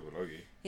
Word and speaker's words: bulgogi: We ate some bulgogi. Is that how you say it? Bulgogi? – Yeah.